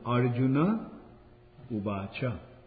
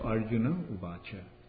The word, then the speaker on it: Arjuna Uvacha: In Sanskrit, when there is an A at the end, that will be pronounced. Like for example, Arjuna Ubacha. Like Arjuna, it ends with an A, therefore it should be pronounced. Arjuna, and Ubacha also ends with an A, so Arjuna Ubacha.